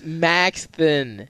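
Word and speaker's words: Maxthon: It's Maxthen.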